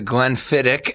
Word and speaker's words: Glenfiddich: It's kind of the Glenfiddich, as I learned it's pronounced.